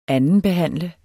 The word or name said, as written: andenbehandle